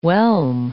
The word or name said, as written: whelm